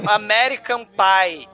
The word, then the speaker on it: American Pie: American Pie. Ah, tá. pai, pai é a mesma coisa, porra. Né? É a mesma pronúncia. Pai, pai.